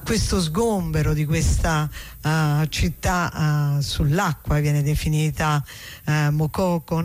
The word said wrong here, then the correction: Makoko: Mokoko con l'accento? – Makoko, sì, non so se con l'accento finale alla francese è Makoko, però probabilmente siccome quello è un paese anglofono sarà in maniera diversa.